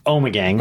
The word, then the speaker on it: Omegang: It's pronounced Omegang.